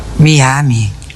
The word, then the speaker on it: Miami: Dopo 20 lezioni di inglese si scrive mi ami, si legge Miami.